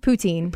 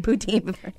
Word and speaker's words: poutine: How do you say it? – Poutine. – Poutine.